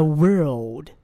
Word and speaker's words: world: 继续 The world. 哎 world 发的不是很好哦。 World. 这个 L 的发音呢你可以这样想象一下。舌尖抵住上齿的后部。